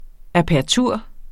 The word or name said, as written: apertur